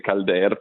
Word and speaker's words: Calder: l'artista statunitense Alexander Calder. Lo dico anche in francese Calder, perché alcuni appunto pronunciano alla francese.